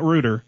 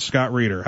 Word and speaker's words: Rueter: It's not Rueter, it's Scott Rueter.